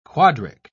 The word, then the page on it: quadric: British English